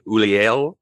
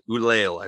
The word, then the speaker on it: Ulliel: Gaspard Ulliel. – I'm not even going to try. – Somebody say it, and they said that is actually Ulliel, I believe is how you pronounce it.